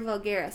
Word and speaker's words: Vulgaris: Enfagaris? – Vulgaris. Oh, yes, yeah. Era Vulgaris, yeah. – I wrote it down wrong.